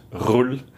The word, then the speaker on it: Roel: And you pronounce it Roll awesome. In English, Roel in Flemish.